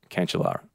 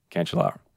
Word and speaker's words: Cancellara: Here is the correct pronunciation. I know I got it wrong. I was really nervous. And he just said, say it, Cancellara. Say it, Cancellara.